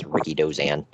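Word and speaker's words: Rikidozan: his name was pronounced Ricky Dozan.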